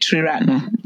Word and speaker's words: Triratna: Am I pronouncing that correctly? – You did, Triratna, yeah.